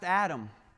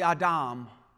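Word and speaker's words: Adam (אדם): In the original Hebrew, you probably have heard it pronounced Adam. It's actually Adam.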